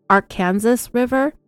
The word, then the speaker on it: Arkansas River: People in the eastern parts of Colorado and Kansas call it Arkansas. People in central Colorado and Oklahoma and Arkansas pronounce it the way we pronounce the state of Arkansas.